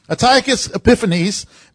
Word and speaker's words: Antiochus Epiphanes: Antiochus Epiphanes, in 167 BC, he was a king.